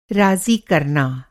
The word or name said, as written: राज़ी